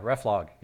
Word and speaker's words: reflog: So reflog, yeah. – Reflog, okay. Yeah. – I'm looking at your notes. I heard you, and I'm trying to process that in my mind. Yeah, reflog.